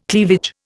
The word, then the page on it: cleavage: Indian